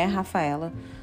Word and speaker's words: Rafaela: Inclusive, para quem não tá entendendo e não me conhece, o meu nome é Rafaela, mas como no Brasil todo mundo me chama de Rafa, aqui no Canadá é muito difícil, as pessoas não conseguem pronunciar o R ou Rafaela, não tem como.